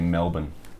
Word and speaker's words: Melbourne: If you're from here, it's actually Melbourne.